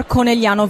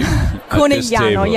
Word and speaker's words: Conegliano: Near Conegliano Valdobbiadene, where the best Prosecco is produced. – And you're the only one who can say that name perfectly. – Conegliano, yeah.